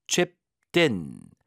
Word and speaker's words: chipped in: 자, 과거형이니까 chipped, chipped in, chipped in. T 발음이 경험화돼서 din 이렇게 되죠. din, chipped in, chipped in.